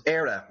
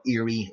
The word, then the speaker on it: Éire: The first one just being the name for Ireland in Irish, which the country was referred to at the time, was Era, not Erie, like you were saying, which is, I presume you got that from Lake Erie, which is spelt very same, except there's a fada over the E in Irish, a fada being like an accent in the Gaelic language.